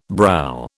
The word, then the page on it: brow: British English